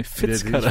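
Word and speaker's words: Fitzcarraldo: Fritz Caraldo. Fritz Caraldo, nee, Fitz Caraldo.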